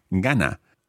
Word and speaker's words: gana: We take off the R of the infinitive and we're left with gana. The stress is on the penultimate syllable, or here, obviously, that's the first syllable in a two-syllable word, gana.